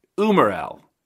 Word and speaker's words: umarell: I don't, But if not, Umarell,